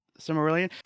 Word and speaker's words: Silmarillion: But the Cimmerillion is that how you say it? Yeah, Cimmer. Silmarillion.